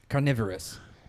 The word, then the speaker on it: carnivorous: Are you trying to say carnivorous? You're just saying it wrong. – No. It's carnivorous. Like that's how it's pronounced.